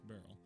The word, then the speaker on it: beryl: I had to look up how to pronounce beryl. Apparently, it's beryl. It's actually a type of gemstone that can refer to emeralds, aquamarines, or others, actually.